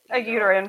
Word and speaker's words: uterine: Is that even how you say it? Yeah, uterine prolapse.